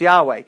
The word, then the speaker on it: Yahweh: And the scholars believe it would be pronounced Yahweh.